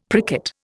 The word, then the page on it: pricket: Indian